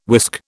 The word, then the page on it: whisk: British English